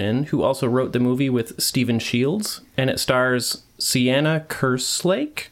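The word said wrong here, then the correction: Seána: Sorry if I butchered that. – It's probably Shauna. – Shauna, yes, you're probably right.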